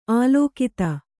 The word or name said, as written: ಅಲಕ್ತ